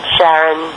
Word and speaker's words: Charon: These indicate only one pronunciation when specifically referred to Pluto's moon with an initial K sound, Karen. Speakers of the languages other than English and many English-speaking astronomers as well follow this pronunciation. However, Christie himself pronounced the CH in the moon's name as SH, and they used the Greek the Greek, after his wife Charlene.